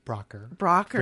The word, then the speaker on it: Braucher: Braucher.